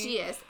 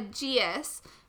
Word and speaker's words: geas: I don't know how to pronounce this word. A geese? A gace? Geas? – I said geas in my head, but I pronounce Lockie as Lockie, so. – All right, we'll go with geas. A geas. G-E-A-S, for those who haven't read this book and are listening, to protect her from enchantment.